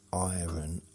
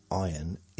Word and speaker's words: iron: Is the R in iron silent? The R in iron is not silent, according to this. You pronounce it like in the words red, run, etc. Wow, absolutely wrong.